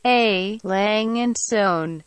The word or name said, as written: A Lange and Sohne